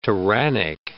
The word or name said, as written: tyrannic